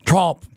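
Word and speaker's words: Tromp: Trump. Trump. Rhymes with chomp.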